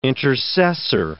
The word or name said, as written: intercessor